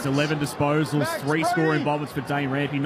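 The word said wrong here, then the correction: Dane Rampe: Was that Dan? – Dane Rampy. A little silent E there at one stage.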